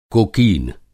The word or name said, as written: कोकीन